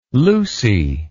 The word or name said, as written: Lucy